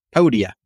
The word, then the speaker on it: Podia: Podia? – Podia.